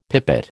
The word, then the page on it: pipit: American English